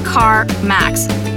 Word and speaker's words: CarMax: It's pronounced CarMax.